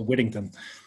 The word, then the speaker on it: Whittington: Yeah, that's a lot of T's. – It is. So, but that's why we don't pronounce them. So it's a lot of D's, so Whittington.